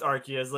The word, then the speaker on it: Arceus: So, fun fact, it's one of the only Pokemon to have an official canon pronunciation because arse is a term for butt. Like, it's slang for ass in Britain. So the translators flat out said, like, yeah, no, it's Arceus.